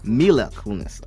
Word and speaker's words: Mila Kunis: It all began with Ashton Kutcher and Mila Kunis. I said that right? – Mila Kunis,